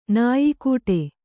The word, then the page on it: ನಾಯಿಕೊಡೆ: nāyi koḍe; Kannada